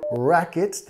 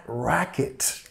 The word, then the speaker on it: racket: Pero racket también usamos para hablar de un ruido muy molesto, ¿no? Who's making that racket? ¿Quién está haciendo ese ruido tan molesto, ¿no? Racket, enfatizando la primera sílaba.